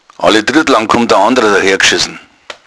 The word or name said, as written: oltriet